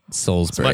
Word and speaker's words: Salisbury: How do you say it? It's like Salisbury steak. – Salisbury.